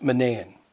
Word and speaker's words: Manaen: And then there's this name pronounced Manaen.